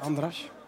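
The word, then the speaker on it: András: Eh, je kent eh, Luca Ooyen met de assist en ook de doelpuntenmaker, Andres Nemet. Andras. Andras moet je zeggen. Ook wel goed gedaan. – Andras in het eh, Hongaars, zo moet je het uitspreken.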